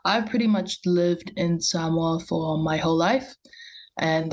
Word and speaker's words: Samoa: So, since you're from, hopefully I pronounce it right, Samoa, You got it.